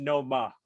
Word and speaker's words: Noma: I'll have that's my only requirement is that I'll we get a Nomo explanation. Update. – And by the way, it's Noma.